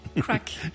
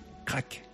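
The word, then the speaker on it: Craic: Crack. – Je vais y arriver à la fin de l'émission. – On ne prononce pas le I. – Crack.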